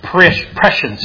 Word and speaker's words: prescience: Prescience is how they pronounce it. At least that's what the internet says.